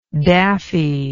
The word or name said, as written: daffiness